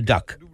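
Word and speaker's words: DUKW: Tenhle obojživelný stroj se jmenuje Duck. Píše se D-U-K-2-T-V, ale vyslovuje se to duck jako kachna v angličtině.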